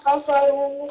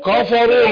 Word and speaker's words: كفروا: هذا الخصمان اختصموا في ربهم الذين كفروا. – كفروا لا اقول كا مش قا انطقها كاف مش قاف كا كفروا.